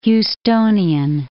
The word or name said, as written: Houstonian